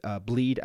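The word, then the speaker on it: Blidh: Is it going to be Anton Blid? Is it going to be Bleed, I should say, is how you pronounce it.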